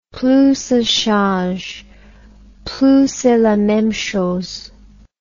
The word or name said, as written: plus ca change plus c'est la meme chose